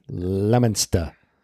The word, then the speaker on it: Leominster: And they pronounce Leominster.